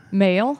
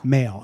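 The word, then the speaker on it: Mael: And the guys in Sparks, how do you pronounce the brother's last name? – Male. – Male, okay.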